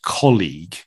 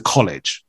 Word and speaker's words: colleague: Now, colleague for the two of us is kind of maybe quite easy to pronounce, but in terms of our experience with Spanish speakers, right, it's so often then just changed or adapted or just pronounced maybe slightly incorrectly, and it kind of goes very easily to college, right? – College.